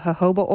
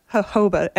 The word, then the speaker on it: jojoba: How do you say that? – Jojoba, and it's spelled J-O-J-O-B-A, oh, yeah. – Which I mistakenly said jojoba oil for a long time.